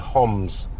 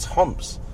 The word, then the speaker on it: Homs: Palmyra, and people go Homs. It's Homs. But maybe I'm wrong.